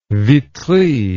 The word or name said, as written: vitry